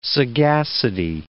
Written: sagacity

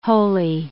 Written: holy

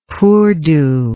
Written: poordo

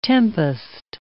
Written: tempest

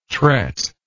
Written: tret

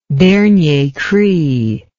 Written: dernier cri

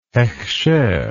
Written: hekhsher

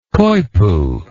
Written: coypu

